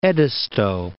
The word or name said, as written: Edisto